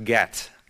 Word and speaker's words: Gad: Gad would be pronounced Gat in Hebrew. Actually, Gat. There's no E, it's more of an aw sound.